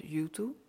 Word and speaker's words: Juttu: Info at en YouTube spreek je uit als YouTube, maar schrijf je als J-U-T-T-U coaching.nl.